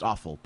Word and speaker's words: offal: Like O-F-F-A-L. Just to clear everyone, because I mean... – Yeah. – That word's pronounced awful, but it's not off.